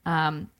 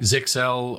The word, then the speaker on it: Zyxel: Since March 2023, Unit 42 researchers have observed a variant of the Mirai botnet spreading by targeting tens of flaws in D-Link, Zelle, and Netgear devices. And did I say that properly? It's I think Zixel. I've heard both of those.